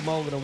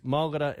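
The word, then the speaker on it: Mulgarda: Our local Mulgara word today is Mulgara. What is a Mulgara? Mulgara is a mullet. You can use Mulgara in this sentence.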